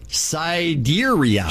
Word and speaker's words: Sidereal: Meanwhile, Sidereal, which is pronounced, Sidereal.